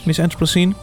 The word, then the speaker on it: Miss Anthropocene: Zeg ik dat goed? Miss Anthropocene? Ja.